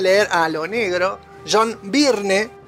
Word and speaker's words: Byrne: Una cosa, Byrne, pero es como Burn, pero raro. Byrne.